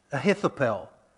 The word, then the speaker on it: Ahithophel: It says, now, there was a guy named Ahithophel. That's how I'm going to pronounce it. I know that's not the Hebrew pronunciation, but it's the English one, I think.